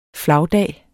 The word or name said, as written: flagdag